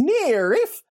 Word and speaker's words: NIIRYF: Anyways, Not If I Reboot You First, that podcast can be found on Twitter at N-I-I-R-Y-F pod. Those are the letters for Not If I Reboot You First. They're pronounced Nierif.